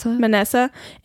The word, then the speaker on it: Manasseh: In his right hand towards Israel's left hand, excuse me, Jacob's left hand, and Manasseh, is that how you say it? – Manasseh, in his left hand towards Israel's right hand, and brought them near to him.